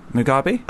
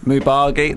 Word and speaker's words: Mugabe: It's Mugabe. It's a weird word. It sounds like you're saying it wrong. Have I got the B and the G right? Mugabe. That's right, isn't it? Mubagi? No, it is Mugabe.